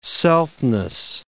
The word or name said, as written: selfness